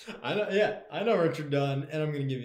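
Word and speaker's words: Dunne: Richard Dunn, or Dune, I don't know how to pronounce his name. – Yeah, you got it right the first time.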